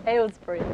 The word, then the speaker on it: Aylesbury: Uh, Kathryn grew up in, I'm going to say this wrong, Aylesbury? – Aylesbury.